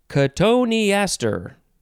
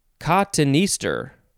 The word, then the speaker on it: cotoneaster: Next word is cotoneaster. Cotoneaster. You could also pronounce it, let's see, cottonister. I think that's the other way. Cottonister. It is spelled C-O-T-O-N-E-A-S-T-E-R. Cotoneaster. That's how I'm going to say it.